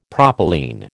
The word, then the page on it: propylene: American English